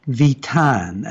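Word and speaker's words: Vieten: Let me remind folks, it's about the half hour, that we are talking to Commander Mary Neal Vitan, and it's spelled V-I-E-T-E-N, V-I-E-T-E-N.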